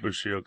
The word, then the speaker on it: Bushido: The allies, allies, therefore, were justified nothing less than unconditional surrender and a complete dismantling of the samurai Bushido. Did I say that right? – Bushido class, yes.